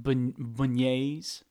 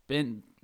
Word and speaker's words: beignets: Every day you'd be going to get some beignets. Those things are delicious. – Some beignets? – Whoa, what was that? – That's what you sound like. – I don't know how to pronounce it. Bennies. – Beignets.